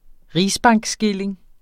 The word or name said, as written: rigsbankskilling